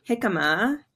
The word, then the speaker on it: jicama: Well, I want to end on talking about jicama. I'm pronouncing it right. Correct? – You are. Yeah.